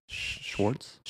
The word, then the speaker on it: Schwartz: Schwartz.